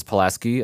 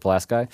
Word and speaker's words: Pulaski: We lived a little bit north of Syracuse, actually, in a small town called Pulaski, which everybody else pronounces Pulaski, except for the people who live in Pulaski. So we would pronounce it Pulaski.